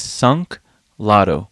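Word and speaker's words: Sunk Loto: Because sometimes it's I say stunk, but... Okay, the name of the band is Sunk Lotto. S-U-N-K, next word, L-O-T-O.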